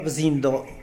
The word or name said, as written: bzindo